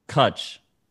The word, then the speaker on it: cwtch: It's a Welsh word. I'm just going with switch because it's just what's coming to mind. I think it's pronounced Kutch. – Kutch. Kutch, potentially Kutch, a Welsh word roughly translating to a hug that creates a safe place.